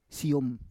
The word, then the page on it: siyom: Roon